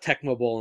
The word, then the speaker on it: Tecmo Bowl: AKA Tecmo Bow. – Yeah, that's how some people did pronounce it.